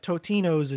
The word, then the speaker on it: Totino's: It was five for Tostitos, 4 for Tostinos, adding an S before the T. And I finally found 3 other people, so 4, that agree that Totinos is the correct way to say it.